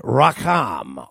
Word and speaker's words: racham: Tender mercy is also a term David uses in the Psalms frequently, which is the Hebrew word racham, R-A-C-H-A-M. That's how you have to pronounce Hebrew. You have to do it gutturally. Racham. That's it.